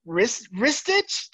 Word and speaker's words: Ristich: Please welcome Taylor Jennings and Anthony Ristich.